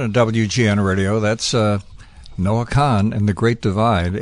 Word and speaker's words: Kahan: And I'm showing that Noah's last name is pronounced Khan, and they use an example as the Mongol ruler Genghis Khan. – Okay. – Really? So Kahan or Kahan, it's Khan.